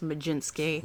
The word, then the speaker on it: Mlodzinski: That is M-L-O-D-Z-I-N-S-K-I. Of course pronounced Majinski.